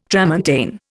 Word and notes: tramontane: Indian

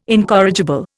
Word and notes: incorrigible: Indian

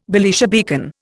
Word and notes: Belisha beacon: Indian

